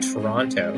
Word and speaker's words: Toronto: And you are the only person I've ever known from Toronto to pronounce that second T. – I know. – Almost everyone I know always says Toronto. – I know. – Toronto. – And that's, in fact, the right way to pronounce it, and I get a lot of ribbing for it when I head back there.